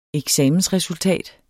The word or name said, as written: eksamensresultat